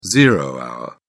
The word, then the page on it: zero hour: British English